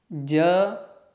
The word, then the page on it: j: sanskrit